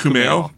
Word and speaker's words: Kumail: I think it's pronounced Kumail. Kumail. That sounds about right.